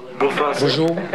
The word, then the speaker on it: Bonjour: Bonjour. Did I say that right? – Bonjour.